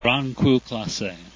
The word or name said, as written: grand cru